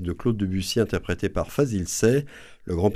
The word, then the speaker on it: Fazil Say: Voilà, Fazil Say, c'est comme ça qu'il faut prononcer. – Hein. Paraît-il qu'il faut dire Fazil Say.